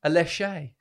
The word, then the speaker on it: Aléshé: Babatunde, Aléshé. – Come on. Grip. – How'd I pronounce that? All right. – Yeah, perfect, man. Perfect.